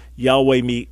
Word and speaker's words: Yahweh: So those would be three arguments that I would say point strongly against Yehovah / Jehovah, and therefore Yahweh remains what we understand to be the best pronunciation.